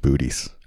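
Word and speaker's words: Boötes: It's pronounced Bootes.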